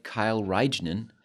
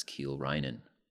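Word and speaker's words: Kiel Reijnen: Kind of looks like Kyle Reignen, but it's Kiel Reinen.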